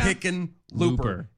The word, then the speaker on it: Hickenlooper: Hickenlooper. It's two syllables, bro. – Not two syllables. It's two separate words. – Yeah, well, yeah, that's... – It's four syllables. – It is four syllables.